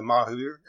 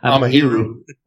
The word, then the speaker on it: Amahiru: Did you write the majority of the music on this album, the Mahir? Excuse me, I try to pronounce that. I keep saying it wrong. – Amahiru.